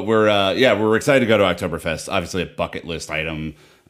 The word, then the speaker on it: Oktoberfest: The first being that I believe it's called, Dan, Oktoberfest. Is it? Did I get that right? Or what's happening there? – Yeah, typically we butcher names and pronunciations on this show, but it's just A-plus effort there.